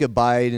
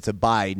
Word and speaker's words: abide: It looks like abide, and I would probably say it, and some people probably pronounce it that way, but it's abide, not a bid. It's abide.